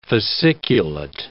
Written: fasciculate

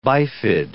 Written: bifid

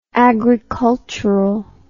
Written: agriculturally

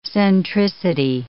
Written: centricity

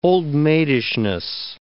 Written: old-maidishness

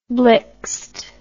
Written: blixed

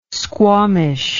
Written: squawmish

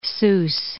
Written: Sousse

